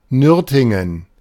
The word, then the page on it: Nürtingen: ˈnʏrtɪŋən